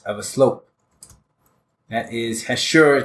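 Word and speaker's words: hachure: The next word is hashur. No, hashur. Emphasis on the second syllable.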